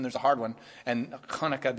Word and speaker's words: Hanukkah: But the way we hear about it in modern times is Hanukkah, and that is the correct pronunciation.